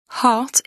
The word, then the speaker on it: heart: Heart » se prononce « heart ». Ce son ressemble au français A, mais il est plus long et nécessite une plus grande ouverture de la bouche. Le R est presque muet.